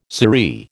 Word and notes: sirree: American English

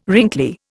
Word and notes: wrinkly: Indian

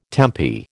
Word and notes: tempi: American English

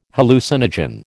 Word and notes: hallucinogen: American English

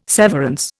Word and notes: severance: Indian

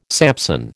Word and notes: Samson: American English

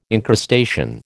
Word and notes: encrustation: American English

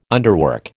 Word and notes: underwork: American English

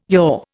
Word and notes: your: Indian

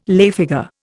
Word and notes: lay figure: Indian

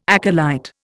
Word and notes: acolyte: Indian

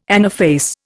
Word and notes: anaphase: Indian

